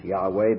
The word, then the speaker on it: Yahweh: which we normally pronounce Yahweh because we don't know how else to pronounce it. The consonants are known, but the vowels aren't certainly known.